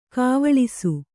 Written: ಗಾವಳಿಸು

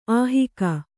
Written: ಅಹಗೆ

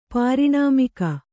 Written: ಪಾರಿಣಾಮಿಕ

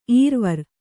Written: ಇರವಾರ

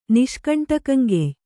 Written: ನಿಷ್ಕಂಟಕಂಗೆಯ್

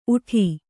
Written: ಊಟ

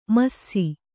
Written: ಮುಸಿ

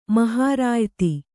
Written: ಮಹರಾಯತಿ

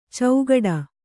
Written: ಚೌಗಡ

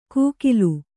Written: ಕೂಕಿಲು

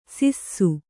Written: ಸಿಸ್ಸು

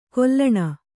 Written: ಘಲ್ಲಣಿ

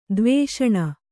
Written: ದ್ವೇಷಣ

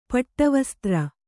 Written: ಪಟ್ಟುವಸ್ತ್ರ

